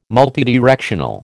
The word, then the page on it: multidirectional: American English